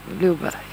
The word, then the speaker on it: lober: Jag påstår att den vänstra lungan har 3 stycken lobber. Lober. Jag vet inte om jag uttalar det korrekt. – Ja, ska det vara lober där, ja.